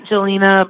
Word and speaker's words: Jelena: On all of it, it's all the same, at Jelena Boxing. So my first name is spelled J-E-L-E-N-A Boxing.